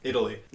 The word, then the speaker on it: idli: There's some Indian breakfast food called dosa and idli.